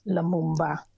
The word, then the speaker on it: Lumumba: So this January, we look at Patrice Lumumba.